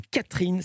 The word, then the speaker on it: Katrijn: Et là, vous avez publié votre premier roman, ça s'appelle Catherine. Ça s'écrit K-A-T-R-I-J-N. Au hasard, je ne m'attendais pas à penser à ça. Elle est hollandaise. – C'est ça. C'est le IJ, c'est du néerlandais, oui.